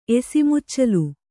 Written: ಎಸಿಮುಚ್ಚಲು

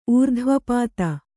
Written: ಊರ್ಧ್ವಪಾತ

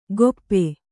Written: ಗೊಪ್ಪೆ

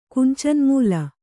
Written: ಕುಂಚನ್ಮೂಲ

